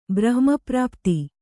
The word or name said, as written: ಬ್ರಹ್ಮಪ್ರಾಪ್ತಿ